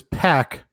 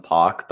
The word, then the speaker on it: PAC: He announced him as PAC both before and after the match. – That's how you're supposed to pronounce his name, actually. But no one ever does. Everyone calls him PAC, but it's traditionally been called PAC dating back to his time in the UK.